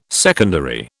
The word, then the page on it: secondary: British English